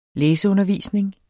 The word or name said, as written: læseundervisning